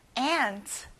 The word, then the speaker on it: aunt: Aunt. 앞에 에 소리가 나요. Aunt.